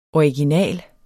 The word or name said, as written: original